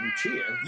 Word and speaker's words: Lucia: Lucia has a CH sound, but it's spelled with just a C.